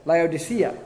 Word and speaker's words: Laodicea: Laodicea.